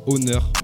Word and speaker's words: Oner: Il s'appelle Honor. Je fais exprès de dire Honor parce que c'est comme ça que ça se prononce.